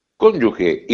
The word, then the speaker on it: cónyuge: Pero no hay una U, es cónyuge.